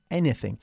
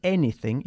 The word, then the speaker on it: anything: Y una vez más, vamos a atraer vuestra atención sobre la pronunciación de la palabra anything. Se dice anything y no anything. ¿Mm? – Mm-hmm. – No es any como tú, sino any. Bien.